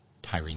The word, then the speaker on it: Tyree: Bernadette lets me know that Tyree Nichols. I was reading it like the name of the ancient Mediterranean city, T-Y-R-E. I was reading it as it's spelled. But it's Tyree Nichols. I will not make that mistake again.